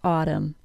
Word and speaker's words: autumn: We can learn a lot from the word autumn, like the AW sound spelled AU, and the alveolar tap, which probably sounds like a quick D sound to most of you, and the silent N in words that end in the letters MN.